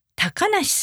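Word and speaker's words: 小鳥遊: それから、もうちょっと変わった名前では、高梨さんという名前があるんですが、これは漢字で、小鳥が遊ぶと書きます。小鳥が遊ぶと書いて高梨さんと読むんですね。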